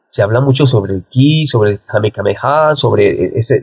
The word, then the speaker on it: Qi: El Qi, no es el Chi, sino el Qi, se pronuncia Qi en chino, existe y ustedes no se imaginan la fuerza que uno puede llegar a alcanzar utilizando el Qi.